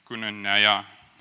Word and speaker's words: koinonia: And just in terms of, I mean preached about community and koinonia. How do you pronounce that? Koinonia. What he said. Koinonia.